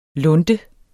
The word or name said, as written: lunte